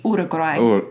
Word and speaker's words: Uruguay: So, if you were to pronounce it with typical A-E-O-U vowels of Spanish, it would be Uruguay.